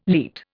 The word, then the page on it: leat: Indian